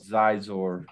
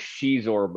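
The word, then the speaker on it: Xizor: So it was I don't know how to pronounce it, but it is I used to always call it Zizor, right? – I think technically it's Shizor, but we always said Zizor, and it's hard for my brain to not say it that way.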